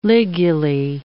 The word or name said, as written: ligulae